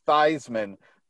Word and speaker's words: Theismann: No, he told everyone that his last name should be pronounced Theismann because it rhymes with Heisman.